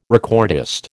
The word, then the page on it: recordist: American English